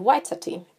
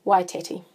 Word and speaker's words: Waitati: and that's called Waitati. And I certainly did not pronounce it that way as I was growing up. It was Waitati.